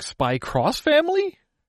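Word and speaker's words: Spy x Family: There's the character X, as in the letter character X, and then Family. I don't believe the X is actually pronounced. – Is it like Spy Cross Family? – Think of it like Hunter Hunter.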